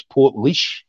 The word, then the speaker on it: Portlaoise: So it's going to be in a place called, now I've been saying this wrong every interview I get. So I believe it's Portlaoise.